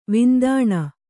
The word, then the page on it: ವಿಂದಾಣ: vindāṇa